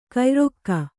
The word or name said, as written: ಕೈಯಾರೈಕೆ